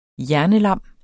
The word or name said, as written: hjernelam